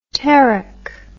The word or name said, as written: terek